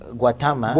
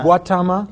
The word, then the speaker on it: Gautama: Gautama. – Gautama.